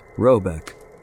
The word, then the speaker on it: Röbeck: The manor house, or Herrgård in question, is to be called Röbeck, pronounced something like Roebeck, though that is not its name.